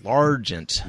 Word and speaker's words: L'Argent: We need to pronounce our R's. So L'Argent, like Steve L'Argent.